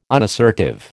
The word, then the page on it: unassertive: American English